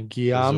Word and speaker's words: Guillaume: Yeah, Guillaume.